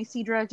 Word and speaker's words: Seadra: Hi, so my name's Isidra, and I'd like people to call me Seadra just for the easier way of saying my name.